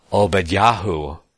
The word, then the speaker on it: Obadiah: We see that the name of God appended on the many end of many of the prophets' names, like Obadiah, or more accurately pronounced Obadyahu, and Obadyahu, right?